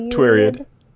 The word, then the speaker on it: Twiriad: Twiriad? – Yep, Twiriad. – Twiriad. I'm sorry, it just sounds like period. And with a W, yeah, and I'm just like, I can't say it properly.